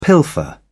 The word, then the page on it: pilfer: British English